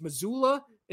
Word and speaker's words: Mazzulla: I think it's Mazzulla is how you say his name.